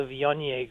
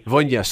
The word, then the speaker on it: Viognier: You're probably talking about the Viognier. – Viognier, sorry, see, that's, I told you I couldn't pronounce it right.